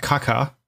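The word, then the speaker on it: caga: Kaga is pronounced kaka.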